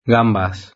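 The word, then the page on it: gambas: Spanish (Spain)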